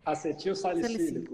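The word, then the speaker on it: acetilsalicílico: Como que se pronuncia isso, doutor? – Acetil salicílico. – Salicílico. Isso, né? – É o AS.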